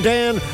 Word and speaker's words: Dan: Also, you can find me, Reverend Dan, on social media.